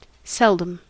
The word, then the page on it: seldom: American English